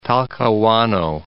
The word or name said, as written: Talcahuano